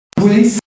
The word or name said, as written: Boulis